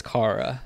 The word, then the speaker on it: Chara: is it Kara or Chara? I honestly don't know how to say it. I believe it's Kara.